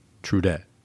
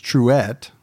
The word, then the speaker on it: Truett: Sounds like a Canadian name. Trudette. – It's Truett. – Oh, like Trudette? – No, there's no D in it.